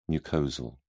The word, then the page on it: mucosal: British English